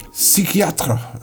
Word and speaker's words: Psychiatre: Let's play something from DJ Psychiatre. That's how you pronounce it in French.